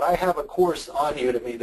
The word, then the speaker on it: Udemy: I have a class, by the way, it's pronounced Udemy, U-D-E-M-Y.